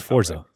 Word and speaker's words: Forza: Oh, about Forza. – Yeah, about Forza. Even though there's no T, it's Forza. Okay. I refuse to put a T in there.